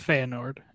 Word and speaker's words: Feyenoord: How do you say that? I don't even know. Feyenoord, something like that. – It's Feyenoord.